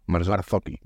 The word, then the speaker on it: Marzocchi: Y unas suspensiones Marzocchi. ¿Lo digo bien? – Marzocchi.